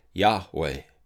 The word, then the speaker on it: Yahweh: some pronounce it Yahweh, with a V being more of a W, and others pronounce it Yehovah, and using the consonants.